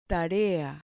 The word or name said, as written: tarea